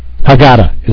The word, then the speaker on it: Haggadah: I don't even know that we're going to be using a Haggadah this year. Haggadah is how I pronounce it.